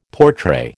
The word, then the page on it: portray: American English